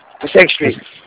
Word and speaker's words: Passaic: Passaic Street? – Passaic Street.